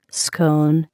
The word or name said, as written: scone